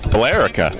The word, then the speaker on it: Billerica: How do you pronounce that? Billerica. There's an E in there. – Billerica. – Billerica, maybe? – Billerica. – Oh, that makes sense. Just leave out the E.